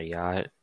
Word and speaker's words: yacht: Like Y-A-T. Yacht.